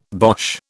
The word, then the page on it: bosh: British English